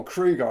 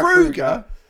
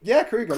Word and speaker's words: Kroeger: Or Kruger, I think we're supposed to say, actually. He revealed everyone's been saying his name wrong for the last 20 years recently, didn't he? But yeah, Hero by Chad Kroeger. Kruger, like Freddy. That's how he said it.